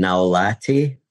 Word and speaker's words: Gnaulati: Enrico, which is Italian for Henry, E-N-R-I-C-O, and then Naulati. Last name is spelled G-N-A-U-L-A-T-I. I have a website, and you can access me that way.